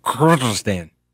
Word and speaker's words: Kyrgyzstan: It's border to the on its northeast border is Kyrgyzstan. And I believe the pronunciation there is Kyrgyzstan.